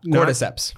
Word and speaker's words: cordyceps: Not cordyceps.